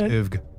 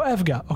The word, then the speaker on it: EVGA: It's pronounced EVGA. – Oh, EVGA. Okay.